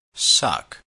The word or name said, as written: suck